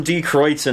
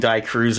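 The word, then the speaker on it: Die Kreuzen: And we heard from D. Kreutz and Rumors. Keeps saying Die Cruz, and I pronounce it wrong.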